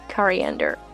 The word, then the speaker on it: coriander: The stress sound is put on the letter A, coriander.